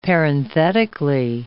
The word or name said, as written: parenthetically